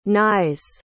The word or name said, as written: Nies